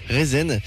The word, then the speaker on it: Rezn: Rezen. R-E-Z-N, ça se prononce comme ça.